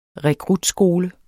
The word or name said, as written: rekrutskole